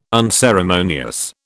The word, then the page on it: unceremonious: British English